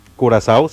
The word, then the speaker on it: Curazao: Juega Alemania contra Curazao. ¿Lo dije bien? – Curazao, sí.